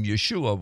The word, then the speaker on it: Yeshua: But the bottom line is Yeshua We say Jesus, most English people do, but a lot of English people now are actually using the name Yeshua when speaking of him, because that's the correct and the literal Hebrew pronunciation.